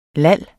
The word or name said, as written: lal